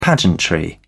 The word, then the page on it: pageantry: British English